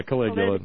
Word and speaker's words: Caligula: But I think what we got is more like Caligula. How do you pronounce it? – Yeah, Caligula.